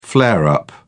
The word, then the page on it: flare-up: British English